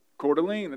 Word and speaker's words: Coeur d'Alene: And I don't even know how to say the name of this city, the city of Coeur d'Alene in Idaho. Coeur d'Alene, it's easier, thank you, Reed.